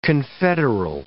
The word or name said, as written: confederal